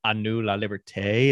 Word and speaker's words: A Nous La Liberté: So number four, I have A Nous La Liberté. Is that how you would pronounce that? Close enough.